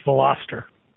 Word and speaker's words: Veloster: It's the middle one there, Glenn. It's Veloster. – It's Veloster. Okay.